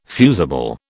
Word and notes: fusible: British English

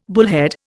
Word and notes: bullhead: Indian

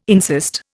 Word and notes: insist: Indian

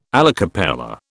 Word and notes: alla cappella: British English

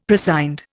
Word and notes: prescind: Indian

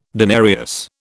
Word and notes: denarius: British English

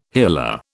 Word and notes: hila: British English

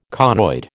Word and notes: conoid: American English

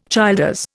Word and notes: Childers: Indian

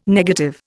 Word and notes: negative: Indian

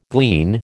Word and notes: glean: American English

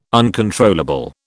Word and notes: uncontrollable: British English

